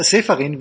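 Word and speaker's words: Čeferin: Alexander Seferin, wie mir Kai gerade gesagt hat, so spricht man ihn aus, mit dem zarten, aber wohlwollenden Hinweis, dass eigentlich Europameisterschaftsspiele ohne Zuschauer keinen Spaß machen.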